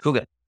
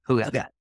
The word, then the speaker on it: hygge: Hygge. – Hygge.